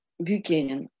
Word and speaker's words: Бьюкенен: а Том Бьюкенен, Бьюкенен, так правильно делать ударение, это его университетский товарищ.